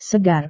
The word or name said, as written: segar